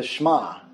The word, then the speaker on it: Shema: It's pronounced the Shema.